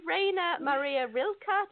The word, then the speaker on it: Rainer Maria Rilke: It's by Rainer Maria Rilke. Did I say that right? – Yes, yeah. Thank you.